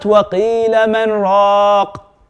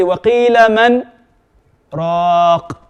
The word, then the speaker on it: وَقِيلَ مَنْ رَاقٍ: And by the way, the Prophet ﷺ has even taught us how to pronounce this ayah. It's not وَقِيلَ مَنْ رَاقٍ. It's actually وَقِيلَ مَنْ رَاقٍ. There's a slight pause in it.